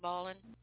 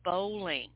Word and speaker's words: bowling: But anyway, he says, "You sure do like balling." Well, it's not balling, guys. It's bowling. They just pronounce it different.